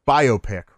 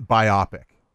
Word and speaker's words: biopic: So apparently, it's pronounced biopic, right? Not biopic. I always thought it was biopic, but it's biopic.